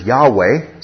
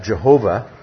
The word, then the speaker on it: Yahweh: This, the Hebrew word here is Yahweh. Sometimes you'll hear it pronounced Jehovah, which is okay, I guess. I prefer not to, because it's actually an error introduced a few centuries back. But Yahweh, it's the covenant name of God with Israel.